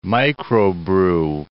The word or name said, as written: microbrew